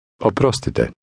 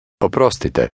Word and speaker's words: Oprostite: Oprostite. – Did you hear the rolling R sound in this word?